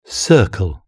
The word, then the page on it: circle: British English